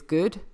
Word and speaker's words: Good: I mean, I've been divorced for 7 years now and my surname is still my married name, which is Good. G-O-O-D.